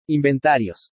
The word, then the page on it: INVENTARIOS: spanish